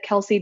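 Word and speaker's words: Celsea: And I'm just at Kelsey Jenkins. And my first name is spelled a little bit funky. It's C-E-L-S-E-A and J-E-N-K-I-N-S.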